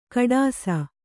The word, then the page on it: ಖೋಡಾಸು: kannada